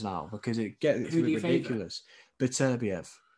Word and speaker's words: Beterbiev: is that how you pronounce it? – Beterbiev. – That's it. Beterbiev, yeah. I want to see that fight badly.